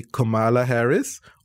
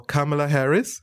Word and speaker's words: Kamala Harris: As a good student who Googled it and researched, there's actually a British pronunciation and an American pronunciation. So it can be Kamala Harris or Kamala Harris.